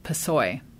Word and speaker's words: PSOE: the initials for which are PSOE, pronounced PSOE.